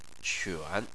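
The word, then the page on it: quan: tone 3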